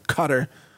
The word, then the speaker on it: Qatar: That Qatar will be the mediator, the nation of Qatar, some people pronounce it Qatar, I think it's Qatar, anyway, announced a four-day humanitarian pause in the Gaza Strip.